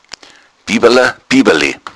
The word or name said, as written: biwala